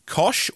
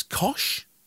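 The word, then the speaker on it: Koç: Now, it's Turkish, so I don't think I pronounce it cock. I think it would be Kosh or Kosh. I think it's a family business. I don't know a huge amount about them. But it's probably not cock.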